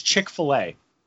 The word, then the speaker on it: Chick-fil-A: So it said as we left, R-Truth is in the Chick-fil-A that's just down the road from Big Kahuna's. – I'm sorry. Point of order. It's Chick-fil-A. – Then why have they got a dash between the F-I-L and the A?